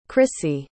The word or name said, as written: chrissie